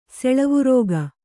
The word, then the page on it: ಸಾಲಾವೃಕ: Kannada